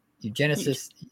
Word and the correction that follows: eugenics: Eugen- am I saying this right? Eugenics. yeah, you're saying it.